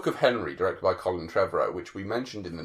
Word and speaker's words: Trevorrow: There's no A in it. – I like the fact you keep There's no Trevorrow. – That's how, it's just Trevorrow. That's how I hear some people say his name, and I always confuse.